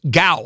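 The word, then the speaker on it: gao (九): 9 is pronounced gu in Cantonese. The digit 9 is, it's pronounced gao, I'm sorry.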